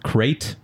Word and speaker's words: Krayt: As a century passed, a new breed of evil Sith grew strong in hiding, led by Darth Krayt.